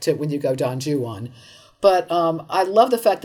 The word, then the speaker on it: Juan: Ju-on rhymes with true one. Yes. – Yeah, that's how you know it's pronounced. Because you can't say he is not the true one. I'll take our friend Don Juan.